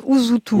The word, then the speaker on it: Usutu: Donc ça s'écrit Usutu, voilà, U-S-U-T-U, mais ça se prononce Usutu. – Usutu, exactement.